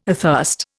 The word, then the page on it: athirst: Indian